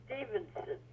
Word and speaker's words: Stevenson: Stevenson. Spell it. S-T-E-V-E-N-S-O-N.